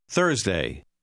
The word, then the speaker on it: Thursday: Thursday. Thurs. Thursday. – Come va la sua pronuncia? Dica oggi è giovedì. Faccia attenzione al suono thur. – Today is Thursday.